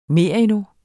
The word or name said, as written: merino